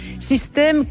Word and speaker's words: System: Mais pas System comme un système, System comme je t'aime. Enfin, ça s'écrit System, S-Y-S-T-E.